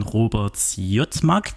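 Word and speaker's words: Robert Siodmak: Und zwar ist das ein Film von Robert Zjotmak. Genau, ein deutscher Regisseur, deshalb die Aussprache.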